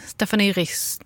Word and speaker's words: Stephanie Rist: Och, jag är lite besviken på din ambitionsnivå av det franska uttalet, men okej. – Ja, hur skulle du säga? – Jag vet inte, jag bara säger att du, det känns inte som att du gav ett försök. – Stephanie Riss.